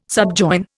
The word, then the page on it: subjoin: Indian